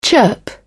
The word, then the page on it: chirp: British English